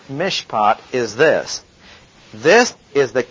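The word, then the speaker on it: mishpat (מִשְׁפָּט): It's pronounced mishpat. M-I-S-H-P-A-T. And it occurs better than 400 times in the Old Testament.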